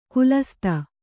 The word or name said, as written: ಖಾಲಸಾತಿ